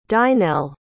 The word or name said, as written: dynel